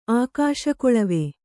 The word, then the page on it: ಆಕಾಶಕೊಳವೆ: kannada